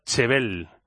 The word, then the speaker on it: Jebel: Quinta opción, su origen en tiene su origen en la palabra árabe Jebel, pronunciada Chebel, que significa montaña.